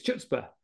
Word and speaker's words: chutzpah: And I'd heard you say it, because obviously a lot of people see this word, C-H-U-T-Z-P-A-H, and think it's chutzpah or some other form of it. So actually this chutzpah is what you've told me how to pronounce it.